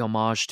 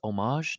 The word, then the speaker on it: homage: So I'm going to do this my own way a little bit, but also, you know, pay homage to him. By the way, it's homage, not homage. I've been saying that wrong.